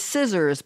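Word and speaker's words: Cizzorz: Happy birthday, and their name is Scissors, but it's spelled C-I-Z-Z-O-R-Z. I know, Laura. Can you imagine that, Roger? Scissors with a C, C-I-Z-Z-O-R-Z.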